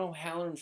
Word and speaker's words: O'Halloran: John O'Halloran 15 on Instagram. That's O-H-A-L-L-O-R-A-N.